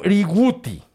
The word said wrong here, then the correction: Huzruiwuhti: H-U-Z-R-U-I-W-U-H-T-I. – Ok, entonces, ¿cómo se dice? – Jursuriuguti. – Jursuriuguti. Ajá. Jursuriuguti.